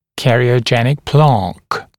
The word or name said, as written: cariogenic plaque